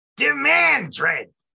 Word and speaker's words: Demandred: It's pronounced Demandred. – No, only if you're actually getting it correct. If you're wrong, it's just a Mandred.